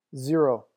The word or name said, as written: zero